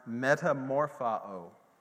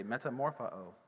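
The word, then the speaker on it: metamorphao (μεταμορφόω): And the word transformed in Romans 12:2 is a Greek word called, it's pronounced metamorphao. Everybody say metamorphao. Yes, or something close to that.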